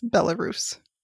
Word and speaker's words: Belarus: Belarus? I don't know how to pronounce it. – It's Belarus.